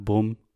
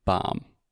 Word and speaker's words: bomb: This is the ah sound, like "prato," shato, hatu. It is not boom. It's not boom, boom. It's bomb. And third thing, we don't pronounce the B. So many times Alexia says bomb Bumb, but it's bomb. – Bomb. – Yeah. So you just need to drill this in your head. The B does not exist.